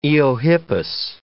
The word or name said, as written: eohippus